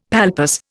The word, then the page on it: palpus: Indian